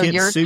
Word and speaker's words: kintsugi: Is it kintsugi? Is that how you pronounce it? – Yeah. Yeah. – So kintsugi.